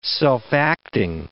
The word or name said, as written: self-acting